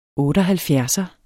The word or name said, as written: 78